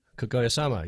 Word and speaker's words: Kaguya-sama: Kaguya-sama? Love is War? – Kaguya-sama, yes.